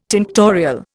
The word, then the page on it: tinctorial: Indian